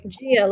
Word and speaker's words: Gia: So I'm like Gia, Like a Chia Pet.